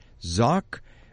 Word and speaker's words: Szoch: The chief author of that is Mary Zock. Am I pronouncing that right, Mary? – That's correct. – Okay, and that's spelled S-Z-O-C-H.